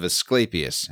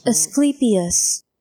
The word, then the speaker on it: Asclepius: Asclepius. – Yeah, you said it right, buddy.